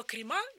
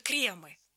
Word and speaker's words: кремы: От слова «крема» меня, например, колотит, потому что кремы. Но если мы выйдем на улицу, абсолютное большинство нам скажет «крема».